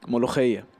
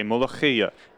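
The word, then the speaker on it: molokhia: Well, me, I'm looking at moloch. Say again for me. How do you say it? – Molocheya. – Okay, molocheya.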